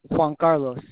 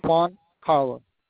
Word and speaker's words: Juan Carlos: Juan Carlos. – You said your name was Carlos? – Juan Carlos. – Can you spell that for me? – Juan Carlos. Juan, J-U-A-N, Carlos, C-A-R-L-O-S.